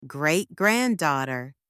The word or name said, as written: great-granddaughter